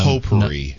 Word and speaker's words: potpourri: First of all, that's not how you pronounce it. – Poopery? – No, it is poopery. It's a product. – Yeah, we have it in our house. – It's to stop your... – It's potpourri.